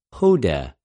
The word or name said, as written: ὡδε